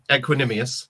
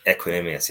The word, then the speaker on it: Equanimeous: The Saint Brown is it Equanimeous Saint Brown? Equin- Equanimeous. – Equanimeous, yeah.